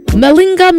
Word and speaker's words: malinger: It is spelled M-A-L-I-N-G-E-R. M-A-L-I-N-G-E-R. Even though it's prefixed with M-A, it is pronounced M-M-Linga.